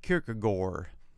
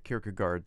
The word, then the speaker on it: Kierkegaard: In Danish, they pronounce it Kierkegaard.